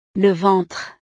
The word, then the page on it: le ventre: frances